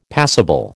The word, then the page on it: passible: American English